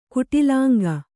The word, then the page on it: ಕುಟಿಲಾಂಗ: kuṭilāŋga